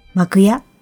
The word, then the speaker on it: 幕屋: 一般的な日本人の会話にはほとんど登場することのないこの幕屋とは、垂れ幕の幕に部屋の屋と書いて幕屋と読みます。